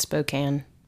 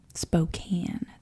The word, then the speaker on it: Spokane: It's Spokane. – Spokane.